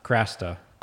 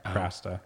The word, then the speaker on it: Krasta: Krasta. – It's the CH. – Yeah. – Right? – It's like KR. That's how you pronounce it, KR, Krasta.